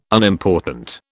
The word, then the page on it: Unimportant: British English